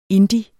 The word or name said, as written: indie